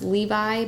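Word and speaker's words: Leovy: So the first book I read was Ghettoside uh, by journalist Jill, uh, I believe her last name is pronounced Levi, but it's L E O V Y for those of you Googling out there.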